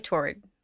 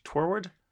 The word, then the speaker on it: toward: No, I say toward. Like forward. – Toward. Toward? – Toward, like forward.